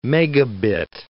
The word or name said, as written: megabit